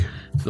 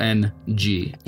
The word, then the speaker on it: Ng: I think it's Ng. The last name is capital N-G. – Yeah, so it's Ng.